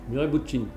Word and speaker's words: Murray Bookchin: Un concept qui existe qui s'appelle le municipalisme libertaire, initié par Mirabuchin, qui a très bien décrit le phénomène. – Redis le nom s'il te plaît. – Murray Bookchin. Murray Bookchin. voilà. J'ai mal prononcé, effectivement. Merci de, me reprendre.